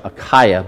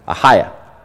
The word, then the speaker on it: Achaia: It was located in a place called, it looks like Achaia, but it's pronounced Achaia.